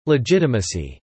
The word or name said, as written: legitimacy